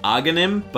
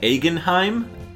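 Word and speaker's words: Agahnim: Aghanim, but I always called it Aghanheim.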